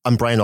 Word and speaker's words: Bryan: I'm Brian Alston, and my name is spelled B-R-Y-A-N. And my last name is Alston, A-L-S-T as in train, O-N.